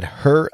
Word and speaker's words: Herr: Now we'll move to Fond du Lac, a newer team, and they play in Fond du Lac at Herr Baker Field, H-E-R-R. I'm not sure how to pronounce that. The German way would be Herr.